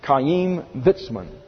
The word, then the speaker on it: Chaim Weizmann: In 1915, Dr. Chaim Weizmann, I'll spell that for you, Chaim Weizmann, Chaim Weizmann is the way it's pronounced.